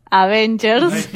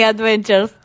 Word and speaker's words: Avengers: Avengers, lo dije bien. – Bien. – No dije Adventures, dije Avengers. Los Young Avengers, entonces, están presentando estos personajes para luego desarrollar esta nueva camada de superhéroes Avengers.